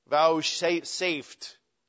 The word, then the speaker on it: vouchsafed: All those that are justified, God, I can't even say it, vouchsafed. How do you say that? Vouchsafed. Vouchsafed. Vouchsafed. V-O-U-C-H. Vouchsafed. But it's one word. So if you say, he vouchsafed, that's two words. It's safe. Vouchsafed.